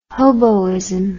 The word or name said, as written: hoboism